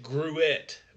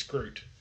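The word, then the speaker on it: gruit: Some people pronounce it Gruet, but it's Groot.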